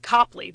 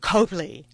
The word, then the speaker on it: Copley: But similarly, you don't know how to pronounce the word C-O-P-L-E-Y, because in Boston, that is Copley. That is not Copley.